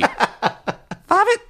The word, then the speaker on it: Favett: Yes, that noise that the horn makes is F-A-V-E-T-T. – Favit.